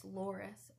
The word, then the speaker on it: Loris: It's Loris. L-O-R-I-S.